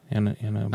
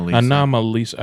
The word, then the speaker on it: Anomalisa: Kind of like that Annalisa. – Anomalisa. – Anomalisa. I always say it wrong every single freaking time.